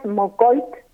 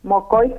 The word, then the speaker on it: Moqoit: Para las redes sociales es Guías Mocoit, Mocoit escrito M-O-Q-O-I-T, digamos que es como se escribe Mocoit hoy, que es en su propia lengua. – Que antes decíamos Mocoví. – Claro. – Exacto, decíamos Mocoví es en plural, sí, sí. No, es siempre Mocoit.